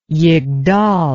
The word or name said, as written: yigdal